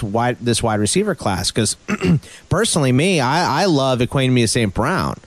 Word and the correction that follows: Equanimeous St. Brown: I don't think I'm getting his name right. I'm probably butchering it. I've heard say it again. I'll tell you. – Equanimeous. All right. Equanimeous St. Brown.